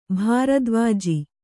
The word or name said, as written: ಭಾರದ್ವಾಜಿ